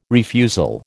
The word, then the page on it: refusal: American English